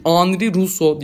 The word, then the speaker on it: Henri Rousseau: Kendisi Belçikalı olduğu ve Fransızca ismi okunduğu için Henri Rousseau diye okunuyor.